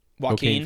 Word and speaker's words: Joaquin: How do you say his first name? Joe Kane Phoenix. – Joaquin Phoenix, whatever.